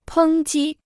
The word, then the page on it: 抨击: pēng jī